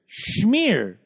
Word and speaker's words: schmear: Schmear with an H. Like, schmear.